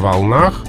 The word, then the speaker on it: волнах: Допустимо э, волнам и волнам, волнах и волнах – волнами и волнами, но вариант с ударением на окончании предпочтительнее. То есть по волнам, на волнах.